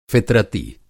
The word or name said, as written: फ़ितरती